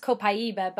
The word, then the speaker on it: copaiba: So, but I would say like right now, copaiba, which is really supposed to be pronounced copaiba, but how we say it here is copaiba, is one that's kind of teetering on that like first place with frankincense.